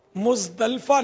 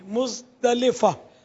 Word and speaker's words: مزدلفہ: عرفات اور مزدلفہ نہیں ہے, مزدلفہ لام کے نیچے زیر ہے.